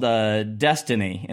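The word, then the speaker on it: DSSTNE: One that's kind of fun that I will highlight specifically for Amazon is one of their Legos is called Destiny, and it's spelled goofy. It's D-S-S-T-N-E.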